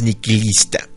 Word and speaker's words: nihilista: Resulta que en latín, que es el idioma del que viene esta palabra, la palabra, la H se pronuncia como Q, como nosotros pronunciamos la Q. Y nihilista viene de la palabra nihil, que significa nada en latín.